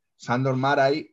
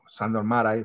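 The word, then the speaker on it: Sándor Márai: Sandor Maray, o Maray, bueno, Sándor Maray realmente, eh, creo que se pronuncia así,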